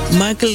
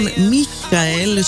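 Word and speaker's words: Michael: Μάικλ, μάλλον Μίχαελ Σούλτ, έτσι για να πούμε και σωστά με προφορά το όνομά του, από τη Γερμανία.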